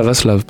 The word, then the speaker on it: Vaslav: Valslav. Ah, Vaslav, pardon.